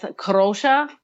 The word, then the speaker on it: Kadrolsha: My name is Kadrolsha Anastasia Carole.